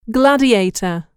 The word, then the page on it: gladiator: [ɡlǽdièitər]